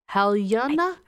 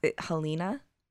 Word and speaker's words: Halyna: The cinematographer, how do you say her name? Her first name? Haliana? – I used to know it. – Hutchins? – I think it was Helena. – Okay. – Helena Hutchins. – It's spelled very interesting. H-A-L-Y-N-A. Yeah.